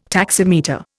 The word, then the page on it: taximeter: Indian